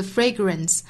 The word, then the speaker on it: fragrance: Forgiveness is the fragrance. Fragrance 这个词，我们注意它的发音是 fragrance. Fragrance. 第一个元音呢是双元音的 a fray fray，后面这个音节包含的是 schwa sound grance grance. Fragrance. Fragrance.